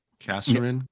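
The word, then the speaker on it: Casarin: I heard Will, I can't pronounce his last name, Kasserin, is that right? – Kasserin. – Kasserin.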